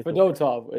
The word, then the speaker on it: Fedotov: Brandon Paul says, I know we signed – was it Fedorov? Is that what you corrected me? – Fedotov. It's a T. It's not an R.